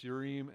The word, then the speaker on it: Urim: Now, I know some people say Urim and Thummim. Hebrew, the I is an long E sound, so it's Urim and Thummim.